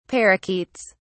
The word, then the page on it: parakeets: British English